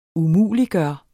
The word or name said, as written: umuliggøre